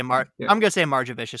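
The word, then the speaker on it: Margevicius: It was Justice, Nick, I say Marjovicius, and I'm sure that's not how I pronounce it. I should have looked it up. Nick. – You got it. – I'm going to say Marjovicius because that sounds badass.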